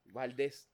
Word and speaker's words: Valdés: Valdés. Valdés. Sí, porque tiene acento en la E.